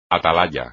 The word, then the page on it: atalaya: espagnol